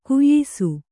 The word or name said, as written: ಘಯ್ಸ